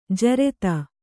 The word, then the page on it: ಜೋರತ್ತು: Kannada